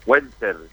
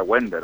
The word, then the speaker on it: Wender: W-E-N... – Sí. – T-E-R. Wender, pero se pronuncia Wender, algo así. Wender.